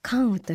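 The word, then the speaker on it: 甘雨: 一つ目はですね、東京都世田谷区深沢のお菓子屋さん、甘い雨と書いてカンウと読みます、カンウさんの焼き菓子缶です。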